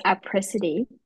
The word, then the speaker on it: Apricity: Actually, you were correct. It is Apricity.